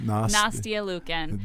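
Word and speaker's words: Nastia Liukin: Nastia Lukin.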